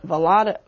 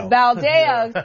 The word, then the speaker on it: Valadao: currently represented by Republican David Valadao, I think I'm pronouncing that wrong. – Valadao, thank you, and being challenged by Democrat Randy Villegas.